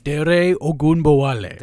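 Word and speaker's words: Dare Ogunbowale: Dere Ogunbowale. – Thank you. You would know the pronunciation there.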